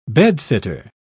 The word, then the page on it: bedsitter: British English